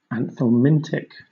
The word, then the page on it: anthelmintic: British English